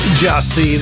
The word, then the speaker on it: Gyasi: Jossi, pronounced Jossi, the song Walk On, spelled G-Y-A-S-I.